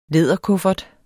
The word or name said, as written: læderkuffert